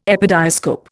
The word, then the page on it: epidiascope: Indian